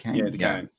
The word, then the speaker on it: Duquesne: Duquesne? I've heard the term once in my life. – Duquesne? – Is it Duquesne? – Yeah, Duquesne.